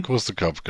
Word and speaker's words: Course de Caps: Course de Cap, genau. Course de Caps, ja. – Wo Michael immer sagt, das S spricht man nicht.